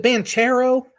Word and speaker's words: Banchero: Paolo is it Banchero? – That sounds right.